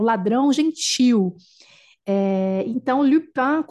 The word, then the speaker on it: Lupin: Lupin, que é o correto a pronúncia, é Lupin, Arsène Lupin, clássico da literatura francesa também, inclusive, não é?